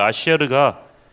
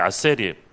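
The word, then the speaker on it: 아쉐르 (אשר): 시편 1편에 있는 복 있는 사람이라는 뜻의 히브리어 아쉐르가 이 히브리식 발음이 이게 아셀이에요.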